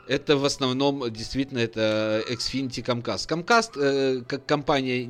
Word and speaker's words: Xfinity: Я тебе скажу больше, что если мы, мы обратим внимание на точное произношение, оно называется Xfinity. – Xfinity.